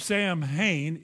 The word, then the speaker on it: Samhain: S-A-M-H-A-I-N. Now, the correct pronunciation of Samhain is Samhain.